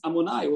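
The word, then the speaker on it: Adonai: So it's written Yud Kei Vav Kei, but it's pronounced Amonai.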